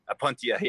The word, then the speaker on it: Opuntia: Oh, Oh, this is the Opetina pachypus. – Okay. – It's actually Opetina or Opetina. I always say it wrong. Opetini, how do you say it? – Opuntia? – Opuntia, yeah.